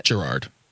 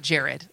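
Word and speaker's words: Jerrod: How did I say it? – Gerard. – Gerard. Sorry. Thank you. I know it's not Jared.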